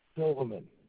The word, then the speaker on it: Silverman: Silverman. – Got it. How do you spell it, the last name? – S-I-L-V-E-R-M-A-N.